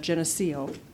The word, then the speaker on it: Geneseo: Geneseo.